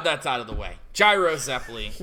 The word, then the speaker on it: Gyro: But yeah, I always say Gyro. The kana backs up Gyro. His full name starts with a hard J.